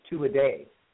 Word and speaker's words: Tuatha Dea: We adopted the phonic pronunciation of the Dea ourselves, but for those listening that just really want to know, if you go by the old Irish, it's two a day.